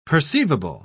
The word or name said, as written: perceivable